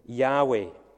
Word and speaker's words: Yahweh: We sometimes pronounce it Yahweh.